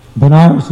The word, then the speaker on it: Banaras: Banaras is actually the modern pronunciation of Varanasi. You can hear how they sound almost the same. Varanasi, Banaras. So it's also called Kashi.